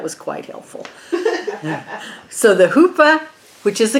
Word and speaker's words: chuppah: Afterwards, they would stand under, okay, right here, a chuppah. It's not a chupa, like I kept trying to say.